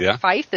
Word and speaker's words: Feith: Doug Feith, the undersecretary for Feith. Is that how you say it? – Mm-hmm. Yep.